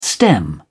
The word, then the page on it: stem: British English